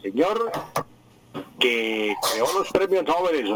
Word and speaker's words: Nobel: en sueco es Nobel, y resulta que en español no nos habían aclarado hasta la época de los años 60 que los apellidos pasan al español con la misma pronunciación que tienen en su idioma original.